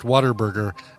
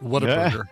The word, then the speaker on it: Whataburger: But it cracks me up and amazes me that it is universally pronounced Whataburger and not Whataburger.